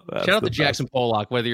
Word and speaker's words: Pollock: Pollock.